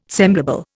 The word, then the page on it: semblable: Indian